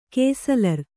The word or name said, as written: ಕೇಸಲರ್